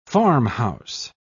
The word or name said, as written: farmhouse